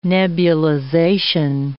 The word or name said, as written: nebulization